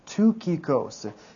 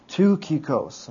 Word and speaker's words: Tychicus: Okay, the next guy then is pronounced Tychicus. Even though there's a Y and a CH, it's actually properly pronounced Tychicus.